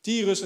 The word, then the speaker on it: Tyrus: Hij ging naar het gebied van Tyre en Sidon. Zeg ik dat goed? Tyrus en Sidon.